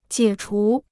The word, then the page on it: 解除: jiě chú